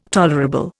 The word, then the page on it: tolerable: Indian